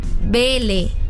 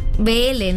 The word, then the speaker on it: Belé: No se dice, se dice Belé. Belé, ¿no? – Sí. – Porque tiene el acento.